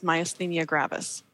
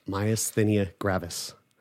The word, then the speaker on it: myasthenia gravis: I live with myasthenia gravis. That's how you say it. – Myasthenia gravis.